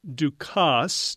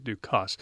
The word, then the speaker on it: Dukas: And Ducasse, D-U-K-A-S, some people will say Ducas, but he actually pronounced it Ducasse.